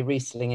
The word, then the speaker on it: Riesling: because they didn't know that you actually Riesling is pr- pronounced Riesling.